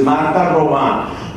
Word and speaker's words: Marta Robin: Se escribe Robin, pero es en francés, Marta Robin.